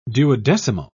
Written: duodecimo

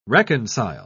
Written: reconcile

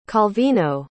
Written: calvino

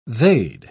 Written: they'd

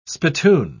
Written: spittoon

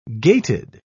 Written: gaited

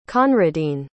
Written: conradin

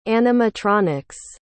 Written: animatronics

